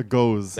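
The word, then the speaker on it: Gose: It's a goes. – It's a goes. It's a German. Goes is German.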